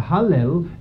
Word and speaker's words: Hallel: The Hallel, of course, is the first two syllables of Hallelujah.